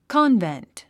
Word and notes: convent: kάnvent; コンベント

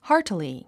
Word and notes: heartily: hάːrtili; ハーティリィ

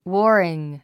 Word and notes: warring: wɔ́ːriŋ; ウォーリン

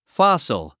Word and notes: fossil: fάs ə l; ファサル

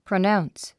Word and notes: pronounce: prənáuns; プラナウンス

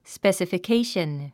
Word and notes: specification: spèsəfikéiʃən

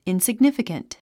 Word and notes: insignificant: ìnsiɡnífikənt; インスィグニフィカント

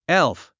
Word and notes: elf: élf; エゥフ

